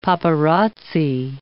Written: paparazzi

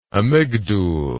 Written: amygdule

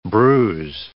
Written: bruise

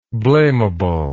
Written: blameably

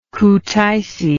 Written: Kutaisi

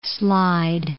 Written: Slide Mountain

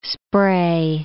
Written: spray

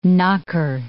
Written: knocker

